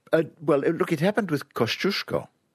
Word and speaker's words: Kościuszko: They don't write the accent on the first S because it's a sh, Kościuszko. But Zdzisław is a...